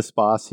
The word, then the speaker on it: Spaß: If you're saying it correctly, it's Spaß.